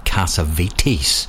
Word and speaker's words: Cassavetes: He's through here with Cassavetes. That's how you pronounce that, writer Nick Cassavetes.